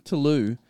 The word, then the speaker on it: Toulouse: Toulouse. – I don't know why I put the S in there. It's it's spelt Toulouse, but said Toulouse.